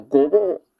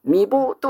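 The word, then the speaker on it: 御坊: 15 番の御謀。これは未謀とは読まないんですね。御謀と発音します。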